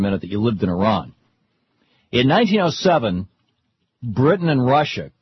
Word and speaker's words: Iran: The situation in Iran, or Iran, I guess is the correct pronunciation,